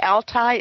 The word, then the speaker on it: Altai: So I've always been interested in the Altai.